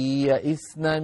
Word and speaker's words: يئسن: إذن خلاصة الكلام واللائي يئسن من المحيض، هكذا تقرأ واللائي يئسن من المحيض يئسنا، فتبقى ياء اللائي لوحدها وياء يئسنا لوحدها، ما في إدغام. يعني الخطأ أن نقول واللائي يئسنا يئسنا، أن نشدد الياء فننطق بياء واحدة مشددة، هذا ما يصح أيها الإخوة.